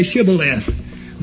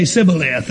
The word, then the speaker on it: shibboleth: Over in Judges 12:6, he speaks of some people that couldn't say shibboleth. They could only say sibboleth.